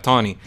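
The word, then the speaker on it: Taney: su nombre se escribe como Tawny pero se, por alguna razón rara se pronuncia Tony e,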